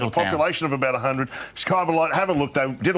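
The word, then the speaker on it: Kybybolite: There's been Kibibolite, Kybobolite, it's Kybibolite. Everyone calls it Kybi, though.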